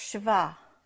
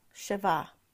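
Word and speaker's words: Sheva: So, anyway, the number 7 is in the Hebrew is called, the letters are Shin, Bet, and Ayin, which it is pronounced Shiva. Shiva. And some say Shiva, but I believe it's Shiva. Sounds like a V, S-H-E-V-A.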